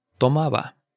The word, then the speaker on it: tomaba: So, So when you say tomaba o estaba and you pronounce that B, it's not the hard B as burro o Barcelona. Listen, tomaba, estaba.